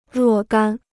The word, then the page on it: 若干: ruò gān